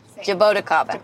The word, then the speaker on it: Jabuticaba: Very close. – Okay. – Jabuticaba. – Jabuticaba. – Yes.